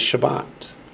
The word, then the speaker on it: Shabbat: That verse is Shabbat. Go, S-H-A-B-B-A-T.